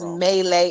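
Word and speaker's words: Meili: what is her name, Trisha Malai? I think that's her name. I might be getting her name wrong. – It's Melee. It don't matter what her name is at this point.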